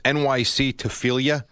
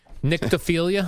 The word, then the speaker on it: nyctophilia: NYC Tophelia. – Nyctophilia.